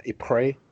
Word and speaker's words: Ypres: I mean, I can never pronounce it properly, but Ypres, Ypres.